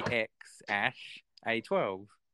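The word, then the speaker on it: X Æ A-12: It's basically X Ash A12. That's what it is. That other one is pronounced Ash.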